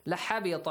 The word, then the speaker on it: لَحَبِطَ: لحبط عنهم. Merge them. ما كانوا يعملون. Very simple. لَحَبِطَ, pronounce the ta correctly, and ha also.